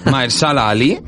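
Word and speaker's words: Mahershala Ali: En este caso, Juan, que es interpretado por, atención, a ver si lo digo bien a la primera, Maersala Ali.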